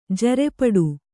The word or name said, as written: ಜರೆಪಡು